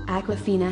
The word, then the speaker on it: Awkwafina: Because she spells it A W, like awkward. And I think that's why she picked it. – Fantasy reboot. Fact check. Awkwafina has confirmed that her name is pronounced Awkwafina. Some people, like Simon, will find this confusing and continue to pronounce Awkwafina wrong.